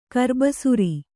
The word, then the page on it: ಕರ್ಬಸಿರು: karbasiru